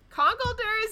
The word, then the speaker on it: Conkeldurr: Conkeldurr is definitely right, because it's like conqueror.